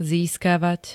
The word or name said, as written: získavať